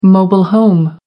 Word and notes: mobile home: American English

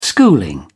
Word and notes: schooling: British English

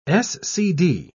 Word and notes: scd: British English

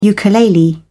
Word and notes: ukulele: British English

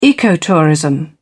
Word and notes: ecotourism: British English

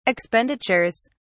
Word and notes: expenditures: British English

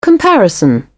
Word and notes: comparison: British English

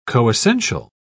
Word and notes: coessential: British English

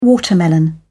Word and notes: watermelon: British English

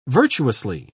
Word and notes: virtuously: British English

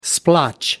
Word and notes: splotch: British English

